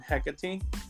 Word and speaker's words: Hecate: It's her name, Hecate. – Hecate.